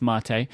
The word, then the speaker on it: mate: It's spelt like mate, but it's mate, I believe.